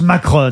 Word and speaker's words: Macron: Alors, je crois qu'on prononce Macron. – Macron, hein, ouais, parce que c'est une marque italienne, mais évidemment, quand on le lisait, on voyait Macron, Macron, et on disait, putain, c'est pas son époque à lui, hein, franchement.